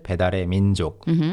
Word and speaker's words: 배달의 민족: And one of the most popular apps would be 배달의 민족, right? – 배달의 민족, 네. 배달의 민족. The pronunciation changes a little bit, 배달의 민족.